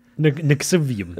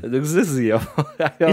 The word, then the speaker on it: NXIVM: It's bad that you still can't pronounce the cult. – Visidium? – Nixivium. – Nixivium. – Is it with an X first and then a V? Or N-X-I-V-M? – Yeah.